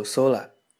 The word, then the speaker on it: 空: 那么我们先来接上地气，日语里的天空念作 sola，想说蓝天可以在 sola 前面加上一个形容词，蓝色的，即青い，青い空。